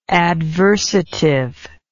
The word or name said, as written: adversatively